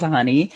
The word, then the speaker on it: Hunni: Nini Leakes released a single called Honey. It's spelled H-U-N-N-I.